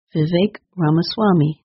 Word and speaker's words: Vivek Ramaswamy: The correct way to say his name is Vivek Ramaswamy.